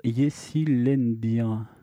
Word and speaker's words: Yeşillendir: c'est Seg avec le titre Yesilendir.